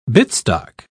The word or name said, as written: bitstock